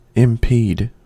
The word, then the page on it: impede: American English